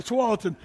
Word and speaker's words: Tualatin: kind of like the Tualatin River. The Tualatin. A little mispronunciation there.